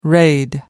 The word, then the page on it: raid: American English